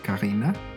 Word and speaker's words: Carina: Welcome to my podcast, Karina.